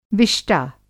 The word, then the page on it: विष्टा: Hindi